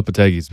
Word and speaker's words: Lopetegui: Julen Lopetegui's been a great manager.